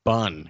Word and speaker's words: Cinnabon: Cinnabon. You know, someone, by the way, speaking of Cinnabon, you know, someone said to me the other day, it's spelt B-O-N, but pronounced bun.